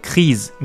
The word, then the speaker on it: crise: クリーゼみたいな音ですね。えー、クリーズみたいな発音します。現代語では。